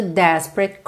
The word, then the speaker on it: Desperate: And the desperate cry, and the juntinho, ok? Desperate, sílaba tônica é na primeira.